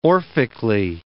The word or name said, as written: orphically